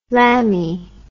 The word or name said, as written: lambie